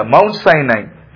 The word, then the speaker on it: Mount Sinai: I don't know how you pronounce it in English. I always wanted, after I came here, but I have not asked the pastor that. Do you pronounce it as Mount Sinai? Mount Sinai, is my pronunciation right? – Yes, yeah.